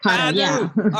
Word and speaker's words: KADO: Did I say that right, Pati? – Yes, that's correct. – KADO.